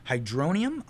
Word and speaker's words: hydronium: And they're talking about, they created a battery that uses hydronium ions. Did I say it correctly? Hydronium. – Yep.